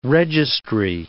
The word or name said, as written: registry